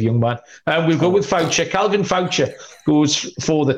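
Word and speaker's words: Faucher: And it is Calvin, how are we pronouncing that? Foucher? – Yes. – Focker? – No. – No, we're not good with Focker.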